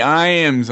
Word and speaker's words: Iams: They call me Iams on all platforms, gaming platforms, and Twitter.